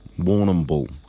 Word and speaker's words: Warrnambool: And we also have Queer Connections, and I want to pronounce this correctly, Warrnambool. Warrnambool. – I mean, are you? – You've done well.